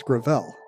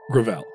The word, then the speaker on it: Gravel: Also, I've been saying it wrong. It's Gravel. – Gravel.